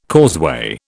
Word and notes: causeway: British English

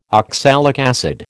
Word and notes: oxalic acid: American English

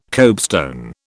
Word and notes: copestone: British English

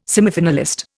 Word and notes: semifinalist: Indian

